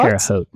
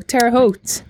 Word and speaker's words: Terre Haute: And I don't know how to say this. Terre Haute. – Indiana State isn't, Terre Haute? – Terre Haute. – Oh, Terre Haute. – That's what we said in Indiana growing up. I suppose there are other ways it could be pronounced if you're not from Indiana.